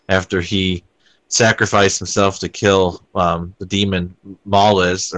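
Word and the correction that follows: Mallus: or Malice, rather, is how it's pronounced, pretty much the show just progressively went into the comedy territory after that.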